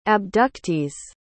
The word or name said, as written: abductees